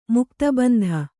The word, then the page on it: ಮುಕ್ತಬಂಧ: kannada